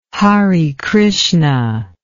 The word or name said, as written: Hare Krishna